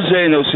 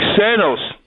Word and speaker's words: Xenos: Actually, it's not Zanos. It's Xenos.